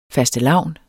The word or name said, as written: fastelavn